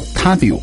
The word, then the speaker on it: Tabio: 那这个牌子是日本的，叫 Tabio 啊，字母是 T A B I O，就是从日语的读音上来说呢，也更接近去旅行吧的意思。可能这个公司想让很多人就穿着他比的袜子走向全球啊，去旅行啊，还是去运动哈，就是大家如果有兴趣的话，你可以去看一下哈，我觉得还是蛮不错的。他比 T A B I O，他在天猫呢都有旗舰店啊，可以去了解一下，好像最近还有活动。